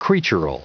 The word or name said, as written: creatural